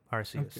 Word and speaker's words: Arceus: Pokemon Legends, was it Arceus? Is that how it's pronounced? – Yep, Arceus.